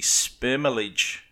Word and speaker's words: spermalege: spermalege. Spermalege, I think I'm saying that right. Sperm, L-E-G-E.